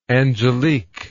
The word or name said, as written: Angelique